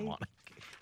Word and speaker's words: Mauna Kea: Mauna Kea. Did I butcher it? What did I say? Mauna Kea? – Mauna Kea. – Okay, sorry.